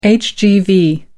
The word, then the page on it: hgv: American English